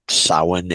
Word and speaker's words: Samhain: Pronunciations do vary a bit, but Samhain, as I'm saying it, even though it seems to be spelled Samhain, is somewhat kind of a universal pronunciation across the European-derived pagan community, so the Celtics and the Normans and whatnot.